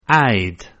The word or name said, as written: I'd